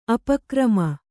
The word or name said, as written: ಅಪಕ್ರಮ